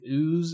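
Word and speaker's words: OOO: And, like, recently I finished Kamen Rider, I think it's pronounced like Ooze. It's O-O-O.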